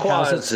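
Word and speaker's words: Jacques: Jacquees is correct.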